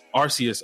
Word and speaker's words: Arceus: With the new Pokemon coming out, Legends of Aces, whatever, that the new company Arceus. Doing it, Arceus, I said Aces. Like Aces.